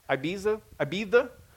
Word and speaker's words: Ibiza: I got a friend of mine that literally just posted video of him dancing in, is it Ibiza? Am I pronouncing that right? What's it? – Ibiza, in southern Spain, right?